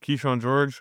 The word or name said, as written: Kyshawn George